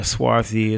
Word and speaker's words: swarthy: Swarthy, Swarthy. You want to look that up? – Rhyme with McCarthy. – Yeah, Swarthy.